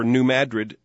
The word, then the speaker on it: New Madrid: named for New Madrid, Missouri, and that is the way that city is pronounced, produced a series of quakes between 1811 and 1812.